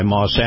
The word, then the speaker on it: Maus: And this portion of the program, my conversation with Chris Markowski, the watchdog on Wall Street, brought to you by Moss Acura. Moss is spelled M-A-U-S.